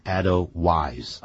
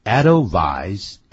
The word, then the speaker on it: Edelweiss: 那么这里头呢，就有一首歌曲叫《 Edelweiss》，也就是我们今天带给大家的这首歌曲。那么的歌词简单，所以我从头到尾念一下，《 Edelweiss》。不要念成 Edelweiss 啊 Edelweiss Edelweiss